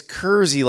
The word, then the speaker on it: Kersey: You're saying it's Kersey? Sort of like, it's Kersey, like Jersey.